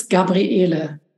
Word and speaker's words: Gabriele: In German, it's Gabriele.